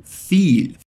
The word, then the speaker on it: viel: Vamos fazer essa frase de trás para frente para você pegar bem a pronúncia, começando pelo fim Repete comigo. Feel. Feel. Que significa muito ou muita coisa. E capricha bem nesse L no final. Põe a língua lá para cima mesmo, como se você estivesse pronunciando o L da palavra lado em português.